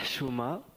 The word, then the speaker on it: Chouma: Je prononce bien Chouma ? – Non, vous ne prononcez pas très bien. – Je m'en doutais. – Ça se dit Chouma. Donc avec un H comme quand on a bu du coca.